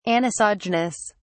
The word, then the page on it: anisogenous: British English